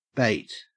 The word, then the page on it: bate: Australian English